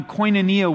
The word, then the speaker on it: koinonia: It's the Greek word koinonia. I'll spell it for you. K-O-I-N-O-N-I-A.